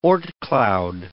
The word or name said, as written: Oort cloud